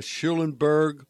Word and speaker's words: Schulenburg: Chip has offices in Austin, uh, Schulenburg, uh, Flatonia, and Hallettsville.